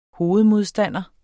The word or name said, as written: hovedmodstander